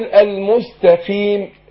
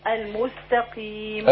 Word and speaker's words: المستقيم: خدي بالك نفرق بين التاء. – ايوه. – والقاف. التاء ما مش مستقيم. لا المستقيم تاء. يبقى انا احرص ان انطق تاء مش طاء. – ايوه. – والقاف مكسوره هتاخد ادنى درجات التفخيم. اقول المستقيم خيم كده.